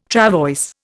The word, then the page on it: travois: Indian